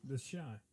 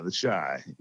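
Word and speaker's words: The Chi: CHI, it's short for Chicago. – Oh, The Shy. Okay. – Yeah, The Shy. Yeah, it's like Shy McBride, the actor.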